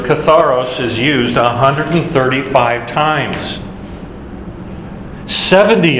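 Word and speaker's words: Katharos (καθαρός): K-A-T-H-A-R-O-S. Katharos. If you start thinking about the pronunciation of that word, what would be a word that you've heard a bit that... – Catharsis. – Catharsis. Exactly. And that actually, catharsis comes from the word katharos.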